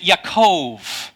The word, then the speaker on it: Jacob: And so there you go, and you say, well, what do we do with names like Jacob? Well, actually, you would pronounce it Yaakov.